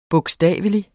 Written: bogstavelig

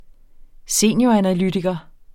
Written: senioranalytiker

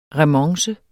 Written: remonce

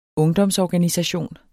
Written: ungdomsorganisation

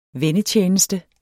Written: vennetjeneste

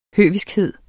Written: høviskhed

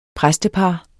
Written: præstepar

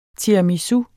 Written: tiramisu

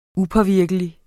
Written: upåvirkelig

